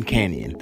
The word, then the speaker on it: Kanion: My name is Brandon Canyon. That's spelled K-A-N-I-O-N.